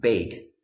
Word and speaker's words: Beth: It's called Beit, B-E-T-H. It looks like Beth, but it's actually pronounced Beit.